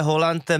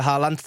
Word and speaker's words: Haaland: Vlastně tenhle hráč, kterého zmiňuješ, v Norsku se vyslovuje Haaland, mimo Norsko, jak on sám říkal, Haaland,